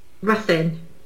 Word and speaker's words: Ruthin: Yes, R-U-T-H-I-N. – Yeah, Ruthin. – Correctly pronounced, please? – Ruthin. – Ruthin. I was going to say Ruthin. Yeah, it's Ruthin.